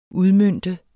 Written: udmønte

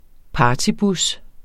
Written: partybus

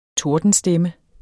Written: TORDENSTEMME

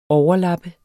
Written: overlap

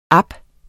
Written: app

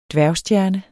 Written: dværgstjerne

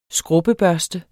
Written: skrubbebørste

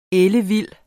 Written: ellevild

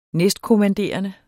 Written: næstkommanderende

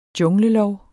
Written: junglelov

